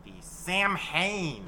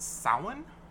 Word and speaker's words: Samhain: Any of our pagan friends in the audience will know right away that that is a mispronunciation of the Celtic word. – What is the correct pronunciation again? – It's actually pronounced Samhain.